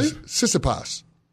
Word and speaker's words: Tsitsipas: How do you say his last name again? – Tsitsipas? – No, Tsitsipas. Tsitsipas. The T's are silent.